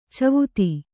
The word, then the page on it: ಚೌವಿಧ: cauvidha